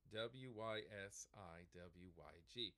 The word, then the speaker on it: WYSIWYG: so uh, this term, it looks like when you would read it in a document, W Y S I W Y G, pronounced WYSGIG. – Yeah, WYSIWYG. What you see is what you get.